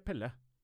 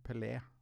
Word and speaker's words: Pelé: Pelé eller Pelle? Nei, Pelé.